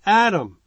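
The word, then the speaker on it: Adam (אדם): But again, man in the Hebrew, you would pronounce it Adam.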